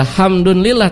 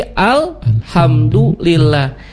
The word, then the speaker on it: alhamdulillah: Pakai al tuh. Tidak hamdun lillah, tapi alhamdulillah.